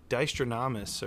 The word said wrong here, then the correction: Dynastradamus: or I hope I'm pronouncing that correct. – Yeah, I think it's Dynastradamus, but his name is Tyler.